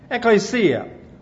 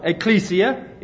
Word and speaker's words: ekklesia: Well, the word church comes from a little Greek word, ekklesia, or ekklesia, if you're using the new Greek pronunciations, and it means an assembly.